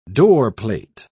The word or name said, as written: doorplate